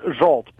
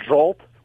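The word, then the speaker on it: Zsolt: It's Zolt, but I go by Z. Z, okay. How you... I don't to pronounce it or spell it, so I stick with Z. It's pronounced Zolt. Remember Zsa Zsa? – Yes. Oh, yeah. As yep. in Gabor, yes.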